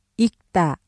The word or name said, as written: 읽다